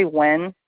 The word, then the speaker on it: Nguyen: U-Y-E-N. – Right. Thank you so much for that. So I got N-G-U-Y-E-N. And how do you pronounce that? – Yep. Well, my husband says Nguyen, but a lot of people say Wen.